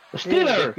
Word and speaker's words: Stieler: Es mejor decirle Tobías, pero es Stiller. Sí. Stiller. S-T-I-E-L-E-R.